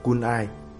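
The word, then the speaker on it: CANI: Kết quả của sự thấu hiểu này, tôi tạo ra một từ gợi nhớ đơn giản: Kunai, Constant and Never Ending Improvement, phát âm là Kunai, nghĩa là liên tục hoàn thiện.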